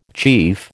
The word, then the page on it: chief: American English